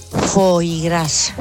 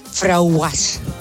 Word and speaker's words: foie gras: Foie gras. – ¿Qué? – Porque decía que tenía un nombre muy raro y ella no lo sabía pronunciar. Entonces lo decía así, frauguas.